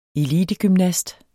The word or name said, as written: elitegymnast